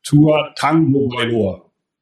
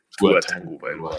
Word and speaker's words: Tua Tagovailoa: So, all together, it would be pronounced Tua Tagovailoa. – Also, das ist Tango. – Tua Tagovailoa, Tagovailoa.